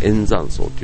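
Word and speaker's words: 燕山荘: で、これあの、円山荘というね、ツバメの山の、ツバメ山荘と書いて円山荘というね、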